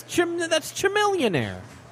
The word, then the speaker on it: Chamillionaire: Yeah, Joel couldn't pronounce Chamillionaire, and that look, it's Chamil. I'm like, that's Chamillionaire.